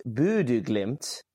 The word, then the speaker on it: Bodø Glimt: I believe, as I saw, it's actually pronounced Bodø Glimt.